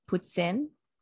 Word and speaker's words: poutine: That's how I just want you to know that it's actually pronounced poutine.